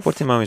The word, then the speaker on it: Portimão: Portimão, oder wie spricht man es aus? Du als Portimão ist gut, ja. Portimão, das ist so das Nasale.